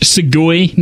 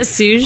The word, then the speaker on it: Suge: I thought you pronounced Segui Knight. – Stop it. – He is a weeaboo. – Wasn't it Suge? – Hold on. He addresses that. AKA Bahamut Fury of Gilgamesh. Not fucking Segui like Juxtaposition thinks.